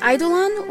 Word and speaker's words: Eidolon: The word of the day is Eidolon, E-I-D-O-L-O-N. It can be pronounced Eidolon or Eidolon.